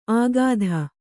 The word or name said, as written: ಆಗಾಧ